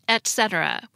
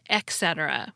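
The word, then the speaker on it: et cetera: First, as you already heard, it's pronounced etc. with a T sound, not etc. with an X sound.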